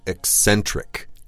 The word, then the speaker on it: eccentric: Right, a perfect word to describe your artist friends, eccentric. – Eccentric.、Mm-hmm. 怎么拼? – Oh, that's, oh, geez, you're going to test me here. E-C-C-E-N-T-R-I-C. – 对,这里有两个 C 啊,大家要注意发音的时候呢是 ec-Eccentric. 对,前面的 C 要发 K 的音。